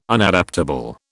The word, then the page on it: Unadaptable: British English